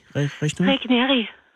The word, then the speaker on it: Regneri: Og hvordan, hvordan udtaler jeg dit efternavn? Regneri? – Regneri.